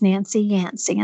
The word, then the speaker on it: Nancy Yancey: And I say, my name is Nancy Yancey. And they go, okay, what is your last name? right. and so I have to spell it out and say, yes, it rhymes.